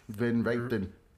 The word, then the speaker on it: Van Richten: Van Richten. – It's not the R, it's the CH. – Van Richten. – No, you can ignore the R. – Van Richten. – Van Richten.